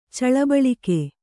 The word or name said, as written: ಚಳಬಳಿಕೆ